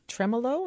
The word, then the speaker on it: tremolo: This is a tremolo. Am I pronouncing it? – Tremolo.